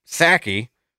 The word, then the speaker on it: Psaki: And it was asked by the new press secretary, Mrs. Psaki. The P is silent.